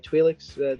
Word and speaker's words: Twi'lek: So, you know, you start off with a shot of the Millennium Falcon with Rey and this Twi'lek. Am I saying that right? Twi'lek? – Yes.